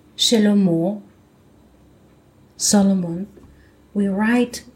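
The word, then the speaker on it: Shlomo (שלמה): But since there is a sound of Cholam, the sound of Cholam is O, up the letter Mem, so the word sounds like Shlomo with Vav.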